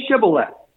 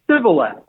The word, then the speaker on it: Shibboleth: And he said, Shibboleth. He was not able to, he could not pronounce it right. He couldn't say the SH sound, sh. He would only say it with an S sound.